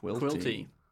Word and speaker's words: Quilty: Quilty. – Just a man. – Rhymes with guilty.